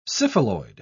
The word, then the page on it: syphiloid: British English